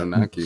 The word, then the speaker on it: Anunnaki: So you're saying this group of people, the Anunnaki, am I saying that right? – Yeah, Anunnaki.